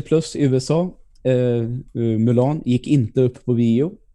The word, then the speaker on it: Mulan: Eller Mulan, som en del säger. Nu är vi där igen. Hur ska det egentligen uttalas? Um. den, ska, den ska uttalas Mulan.